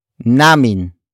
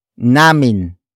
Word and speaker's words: namin: The answer is introduced by the next word, namin. Namin. Namin. Namin is spelled as N-A-M-I-N.